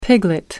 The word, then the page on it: piglet: American English